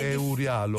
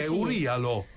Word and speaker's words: Eurialo: Di solito si dà l'accento sulla parte finale. – Eurialo. – Eurialo.